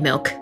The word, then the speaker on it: MILCK: My name is Connie Lim, and I go by the artist name Milk. It's spelled M-I-L-C-K. And yes, like the substance that women create to nourish the next generation and for non-binaries as well.